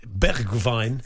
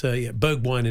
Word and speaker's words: Bergwijn: Likewise, well done to Tottenham's new sign and Stephen Bergwijn, because they asked him how to pronounce his name. And he said it's Bergwijn. It's a bit like Greta Thunberg in Dutch. And he said, but it's Bergwijn in English.